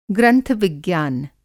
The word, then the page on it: ग्रंथ-विज्ञान: Hindi